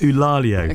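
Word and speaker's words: Eulálio: Eulalio. I asked my son, and he assures me it's pronounced Eulalio.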